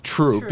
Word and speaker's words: Throop: So we've obviously accepted that troop is troop because that's how the locals call it, but it's certainly not how it's spelled.